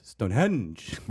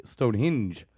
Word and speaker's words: Stonehenge: Like, they do a weird, like, they do like a... they do like a weird emphasis on the second syllable on the henge, and what? Then they pronounce it with more of like a long E, Stonehenge.